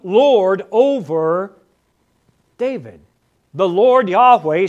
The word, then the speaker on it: Yahweh: We generally pronounce it as Yahweh.